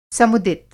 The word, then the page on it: समुदित: Hindi